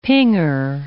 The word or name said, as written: pinger